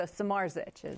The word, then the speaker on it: Smartich: I tell people ignore the D because there's a D and a Z together, so Smartich.